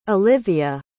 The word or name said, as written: Olyvia